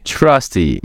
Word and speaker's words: Trusty: All right, so, Trusty, am I saying that right? – Trusty. Austin Trusty.